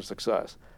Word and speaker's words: CICESS: When we started that, you will see in the article we said, the Central Illinois Center of Excellence for Secure Software, CICESS, we pronounce it success.